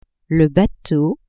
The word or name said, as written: bateau